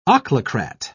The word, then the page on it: ochlocrat: British English